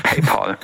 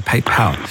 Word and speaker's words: PayPal: PayPal, gute Idee. – PayPal, sorry, ich vergesse jedes Mal, dass ich es falsch ausspreche. PayPal.